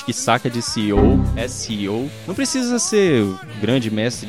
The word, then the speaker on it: SEO: É SEO que se pronuncia.